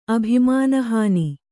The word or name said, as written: ಅಭಿಮಾನಹಾನಿ